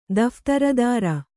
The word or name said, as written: ದಫ್ತರದಾರ